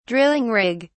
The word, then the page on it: drilling rig: British English